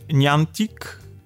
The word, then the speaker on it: Niantic: E, e, tak, bo generalnie jakby deweloperem jest e, firma, która, którą się wymawia e, Niantic, E, generalnie, e, generalnie to jest firma, która zasłynęła już mm, kiedyś aplikacją właśnie mm, takiej rozszerzonej rzeczywistości, takiej gry miejskiej.